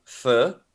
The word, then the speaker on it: pho: There was another time when we got pho, which I pronounced it right.